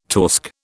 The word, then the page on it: torsk: British English